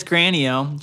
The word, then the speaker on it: Granio: Now, do we want to pronounce it Granio or like Granio? – No, it's Granny-o. She does not accept her Italian heritage.